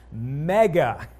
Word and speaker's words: mega: The Greek word here is pronounced mega.